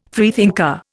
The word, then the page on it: freethinker: Indian